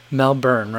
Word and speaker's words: Melbourne: I always want to pronounce all the vowels that are in there. I want to say Melbourne, but I don't think that's how people from there say that. So I want to respect that.